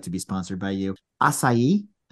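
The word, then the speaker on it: açaí: I believe it's acai. – It is acai.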